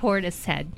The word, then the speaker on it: Portishead: Portis Head.